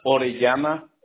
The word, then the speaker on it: Orellana: It's Orellana, O-R-E-L-L-A-N-A.